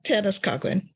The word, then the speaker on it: Tanys Coughlan: Yeah, Tanys Coughlan.